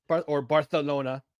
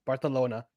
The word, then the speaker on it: Barcelona: Barcelona. Sorry. Try to say it right. Barcelona.